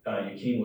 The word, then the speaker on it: Jakin: But in the Bible, it's spelled Jakin, and Yukine was one of the pillars in Solomon's temple.